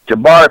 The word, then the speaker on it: Jabari: Jabari Parker, I think that's how you pronounce his first name.